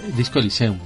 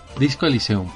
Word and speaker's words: Disco Elysium: Disco Elysium, Lo he dicho bien, ¿no? O, he dicho otra cosa. Es que tengo un nombre, ¿qué? He dicho Disco Elysium. Disco Eliseum perdón.